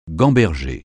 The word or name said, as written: gamberger